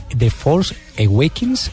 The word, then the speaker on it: The Force Awakens: Le encuentro otra tendencia, The Force, Awake, The Force Awakens, ¿se pronuncia así? Mm, sí.